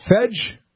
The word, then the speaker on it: Fej: The name I hear has F-E-J. Is that Fedge? – That is Fedge. – Oh, good. I want to make sure I said it right. Fedge, you're on the Cloverdale Big Flavor Hotline.